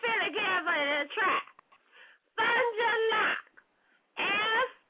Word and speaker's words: Fond du Lac: Fond du Lac.